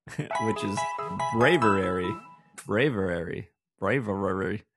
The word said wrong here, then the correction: Braviary: I think I said it right, right? – Braviary. – Braviary. That's what I said.